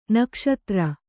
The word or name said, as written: ನಾಗಾಸ್ತ್ರ